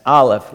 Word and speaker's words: Aleph: That's Aleph, like Olive, but Aleph, Aleph, Aleph. And that sounds like an F. Aleph looks like this. Kind of looks like an N, but it's actually silent. The Aleph is silent.